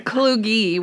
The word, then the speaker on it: Kluge: It's Kluge with a hard G and a long E, but that's okay.